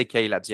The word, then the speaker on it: Cailabs: Is it pronounced Cailabs? – Yeah, we do say Cailabs, yeah.